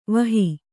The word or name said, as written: ವಾಹಿ